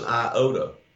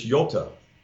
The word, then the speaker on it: iota: Well, that's an iota. That's not the way the Greeks pronounce it. It's yota, which is the Hebrew yod. It's a Y.